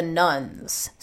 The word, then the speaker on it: nones: Well, I know in reading your book, you talk about the nuns. So what are you referring to there? And why are their numbers rising so dramatically? – Sure. So the nuns, and that's spelled N-O-N-E-S, not nuns like Catholic nuns.